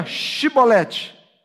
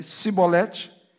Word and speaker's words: Chibolete: Diziam, então diga Chibolete. Se ele dissesse Sibolete, sem conseguir pronunciar corretamente a palavra, prendiam-no e matavam-no no lugar de passagem do Jordão.